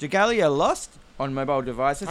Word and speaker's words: Dragalia: How do you say this word? – Dragalia.